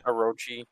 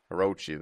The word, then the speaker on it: Orochi: Orochi. – Orochi.